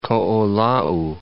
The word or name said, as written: Koolau Range